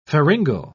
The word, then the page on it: pharyngal: British English